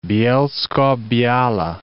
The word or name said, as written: Bielsko-Biala